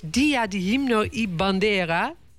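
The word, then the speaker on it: Día de Himno y Bandera: Het is Dia de Himno y Bandera. Zeg ik dat goed? – Klopt helemaal.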